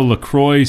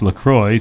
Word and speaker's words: LaCroix: Officially, it's pronounced LaCroix, so it rhymes with enjoy. Not La Croix cool. Like it would be in the French.